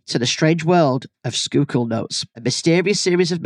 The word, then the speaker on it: Schuylkill: Schuylkill is the correct pronunciation of this word. It is actually spelled S-C-H-U-Y-L-K-I-L-L.